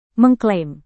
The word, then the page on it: mengklaim: Indonesian